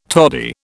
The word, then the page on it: toddy: British English